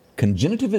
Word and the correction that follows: cognitive: is that right? – Cognitive.